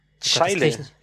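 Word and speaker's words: Zscheile: Und zwar ist der von Falk Z, Entschuldige, Falk, Falk Zei, Scheile. – Scheile? Das kriege ich kaum ausgesprochen. – Also Z-S-C-H und dann Eile.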